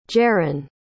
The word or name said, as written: jaran